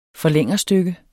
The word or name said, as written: forlængerstykke